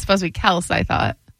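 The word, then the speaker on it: Kelce: It's actually Kels with a Z is how it's supposed to be pronounced. It has a Z on the end, you know, so Kels, Travis Kels.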